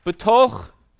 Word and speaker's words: betoch: Betoch, say betoch. Toch rhymes with toch. That's it. If I don't have a rhyme for you, this you'll get it twice. Betoch, B-E-T-O-C-H, phonetically, in the midst.